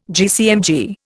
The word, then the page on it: GCMG: Indian